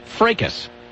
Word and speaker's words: fracas: Oh, by the way, John emails in and says it is pronounced fracas. A fracas ensued.